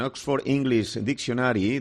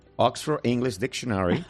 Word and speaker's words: Oxford English Dictionary: Por si pillamos a alguien despistado, en Oxford English Dictionary define... – Qué bien lo pronuncias, ¿eh? A ver, dilo de nuevo. – Oxford English Dictionary.